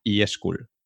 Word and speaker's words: eSchool: En abril de 2018 lanzas por primera vez tu programa insignia que se llama eSchool. E, eh, I, pronunciado en inglés, ¿no? E, y que es la E de Etsy, no eSchool, Eso es.